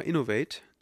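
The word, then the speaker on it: Inov-8: Und zwar sind das von, von der Firma Innovate. Die schreibt man Innov-8.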